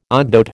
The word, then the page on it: ogdoad: American English